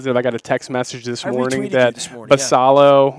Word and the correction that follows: Basallo: Basayo. – Okay, yeah, I want to say it right. Basayo.